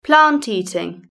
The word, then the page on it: plant eating: British English